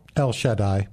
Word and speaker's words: El Shaddai: How would you pronounce El Shaddai? – El Shaddai. – El Shaddai? So you would put the emphasis on the first syllable?